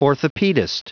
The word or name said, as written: orthopedist